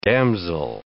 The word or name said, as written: damsel